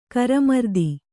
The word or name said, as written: ಕರ್ಮಾರ್ಥ